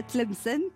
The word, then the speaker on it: Tlemcen: Vous êtes né de l'autre côté de la Méditerranée, à Tlemcen, pendant la guerre d'Algérie.